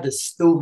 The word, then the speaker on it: Dastu: So we got the Stew, man. It's spelled D-A-S-T-U, man, but it's pronounced the Stew, man, short for the studio.